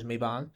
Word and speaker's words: Mabon: So Maybon, however you want to pronounce it. I like Maven personally, but I'm pretty sure the actual pronunciation is Maybon.